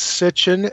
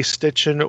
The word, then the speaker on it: Sitchin: Uh, it's it's Sitchin, S I T C H I N, but it's easy to to to say Stitchin or or spell it with an e at the end, uh, uh, or, or, or an i.